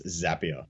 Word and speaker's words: Zapier: They're saying it wrong. You've got that absolutely right. It's Zapier. – Is it? Because it's a zap, right? And you don't you don't create a zape.